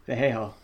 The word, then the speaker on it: Vallejo: Vallejo.